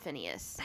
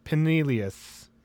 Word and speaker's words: Pinellas: There's no way that's pronounced Phineas. – Pinellas? – Pineas? – Pineless. – Okay, I'm going with... Let's see here, because it'd be... – Pinellas. Pinellas. – Okay, no, it's double L.